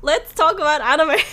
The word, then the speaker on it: anime: So, I want to talk about how I used to pronounce it first, because no one told me it was anime. There's no accent on the E.